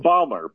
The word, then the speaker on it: Baumler: B-A-U-M-L-E-R? – B-A-U-M-L-E-R, Thomas Jude Balmer, yep. Okay? – I think that's pronounced Balmer, but I may be wrong. – Balmer. Yeah, you're correct.